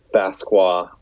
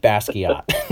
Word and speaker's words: Basquiat: Oh, want to shout out the use of the Basqua artwork on the Strokes album too. Love that. – I want to shout out you using the pronunciation Basqua instead of Basquiat.